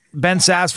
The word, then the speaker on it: Ben Sasse: Ben Sasse from Nebraska.